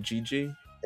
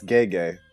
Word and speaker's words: Gege: I know you have the way you pronounce things. – Yeah. – With GG. – It's Gege.